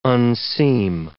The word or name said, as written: unseam